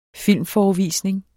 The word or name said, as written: filmforevisning